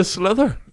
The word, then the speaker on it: sliotar: What is the ball in hurling called? Is slither? – Yes. It's a slither.